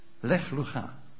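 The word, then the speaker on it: Leg lucha (לך לך): Leg lucha. Zo spreek je het uit.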